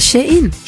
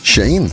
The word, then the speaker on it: Shein: Det heter Shein. – Shein. – Som she och in.